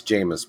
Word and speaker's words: Jameis: Yeah, it's Jameis, by the way.